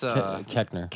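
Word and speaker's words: Koechner: Let's see, Diane Guerrero, Anna Baryshnikov, Judd Hirsch, Jermaine Fowler, Maz Jobrani, David Kochner. – What channel is it? – Kechner? – Kechner, yeah, I know his face, but not how to pronounce his name. – It's Kechner.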